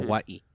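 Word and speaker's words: Hawaii (Hawaiʻi): because you're seeing it more common now with the apostrophe between the two I's in mm-hmm. Hawaii, so you have to have that hard stop to say it right.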